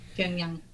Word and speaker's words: Pyongyang: Pyongyang.